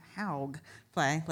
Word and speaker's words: Hawgfly: Hogfly Productions. It's spelled H-A-W-G-F-L-Y. Hogfly, like your redneck friend.